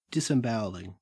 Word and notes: disembowelling: Australian English

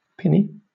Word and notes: pinny: British English

